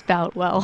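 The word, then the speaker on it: Boutwell: She joins us now from the historic Boltwell Auditorium, I hope I said that correctly, to give us some reaction. Did I get it right, Tanya? – You know, Tony, we've heard several different ways of pronouncing it today. It's Boltwell.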